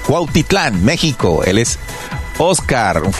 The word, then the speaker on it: Cuautitlán: Ya lo dije bien, no se va a burlar de mí. No lo dije mal. Cuautitlán, México.